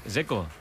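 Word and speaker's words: Džeko: Porque DZ, ¿cómo se pronuncia? – Dzeko. Bruno Dzeko. Dice que es Dzeko. – Como tirando a J Dzeko o algo así.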